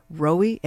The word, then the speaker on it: ROWI: ROWI, everybody, is the way to pronounce that.